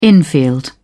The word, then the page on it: infield: British English